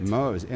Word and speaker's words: Mohs: The center specializes in Mohs. Is that how you pronounce that? – That is correct. – Mohs, M-O-H-S.